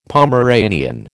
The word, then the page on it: Pomeranian: American English